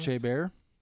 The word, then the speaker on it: Chabert: And Lacey, how do you say her name? Chabert? – I think it's Chabert, isn't it? – Chabert. French? – That's the French way to say it.